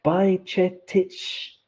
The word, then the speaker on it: Bajčetić: The first C in Bajčetić has a little V on top, which is like Czech, where you pronounce it as a ch, like church. So it's Bajč, and then E is E, like E, Bajčetić, Bajčetić, and I can't do the last one.